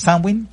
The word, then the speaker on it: Samhain: pues su pronunciación correcta es Samhain, y lo que significa a grandes términos es el fin de la mitad iluminada del año, o el fin del verano.